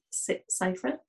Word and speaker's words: Seyfried: And Amanda Seyfried. Is that how you say her name? Yeah.